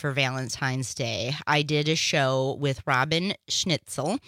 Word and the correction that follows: Schnitzler: Oh, I said her name wrong. She cursed me because I was so afraid last week that I would say it wrong. Now I'm forever saying it wrong. Schnitzler. That's what it was.